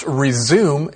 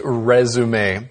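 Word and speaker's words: résumé: So it's not pronounced resume, it's pronounced resume. There are other English words like this that actually come from French.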